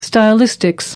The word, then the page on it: stylistics: American English